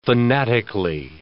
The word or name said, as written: fanatically